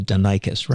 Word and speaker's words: Denikos: And for those of you folks listening, Denikos is spelled D-E-N-I-K-O-S. And it's not Tony Denikos, it's Tony Denikos, right, Tony? – That's right.